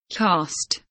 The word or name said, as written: cost